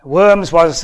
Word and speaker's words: Worms: But Worms, I think is the right pronunciation. Worms was a town in Germany.